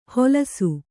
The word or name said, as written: ಹೊಲಸು